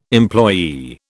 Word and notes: employee: British English

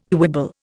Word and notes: quibble: Indian

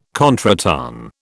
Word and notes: contretemps: British English

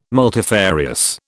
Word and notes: multifarious: British English

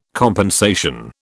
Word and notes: compensation: British English

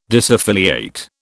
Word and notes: disaffiliate: British English

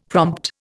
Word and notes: prompt: Indian

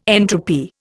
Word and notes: entropy: Indian

